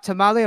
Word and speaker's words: Tamale: Wait, now talk about, is it really tamale, like hot tamale? Am I saying it right? – Yeah, it's tamale. You are right.